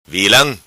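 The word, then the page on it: Vilam: patua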